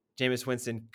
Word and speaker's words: Jameis Winston: I don't have the highest opinion of Jameis Winston, but if there were a team that Jameis Winston could be successful in.